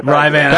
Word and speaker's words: Rivanna: Rivanna.